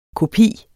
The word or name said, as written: kopi